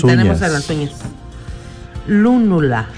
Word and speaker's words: lúnula: Lúnula, con un acento en la U.